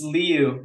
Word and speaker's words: Liu: Oh, I looked up how to pronounce it. Simu Liu. I think just Liu, actually.